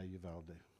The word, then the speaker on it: Uvalde: Is that how you say it? – Yeah, Uvalde.